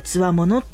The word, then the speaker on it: 兵: これ兵隊の兵と書いて強者って読むんですよね。